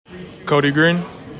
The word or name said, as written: KODI GREENE